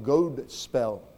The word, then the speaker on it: Godspell: If you go back and look it up in Webster's dictionary, you find that the Old English was Godspell. G-O-D-S-P-E-L. Long O, Godspell, which means good story, good news. Somewhere along the line, the D got dropped. The O was shortened to a short O, and we have gospel, good news.